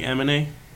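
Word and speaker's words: Aminé: Amine. You like Amine? – Is that how you say his name? That is.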